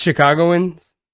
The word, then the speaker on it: Chicagoans: It's Chicagoans? – With an accent on the car. Chicagoans.